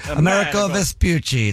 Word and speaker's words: Amerigo Vespucci: Amerigo Vespucci.